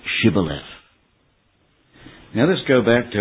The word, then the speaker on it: Shibboleth: This was because their dialect couldn't pronounce the SH in the word Shibboleth. And instead they would say Sibboleth.